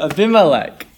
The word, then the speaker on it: Abimelech: Abimelech.